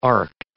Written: arced

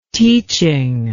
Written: teaching

